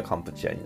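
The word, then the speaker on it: カ ン プ チ ア (កម្ពុជា): そうそうそう、あのー、だから現地の言葉でカンボジアっていう時はどっちかというと発音的にはカンプチアになるみたいですね。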